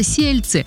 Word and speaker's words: Сельцы: Населенный пункт называется Сельцы. Именно вот так ударение на первый слог.